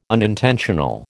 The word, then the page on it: unintentional: American English